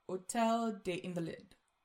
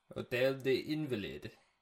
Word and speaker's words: Hôtel des Invalides: And on the morning of July 14th, they go to a place called Hôtel des Invalides. And I'm saying that wrong, I know. – Hôtel des Invalides.